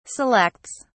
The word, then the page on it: selects: British English